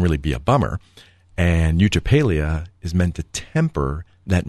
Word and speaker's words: eutrapelia: And it's called The Virtue of Eutrapelia.